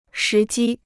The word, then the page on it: 时机: shí jī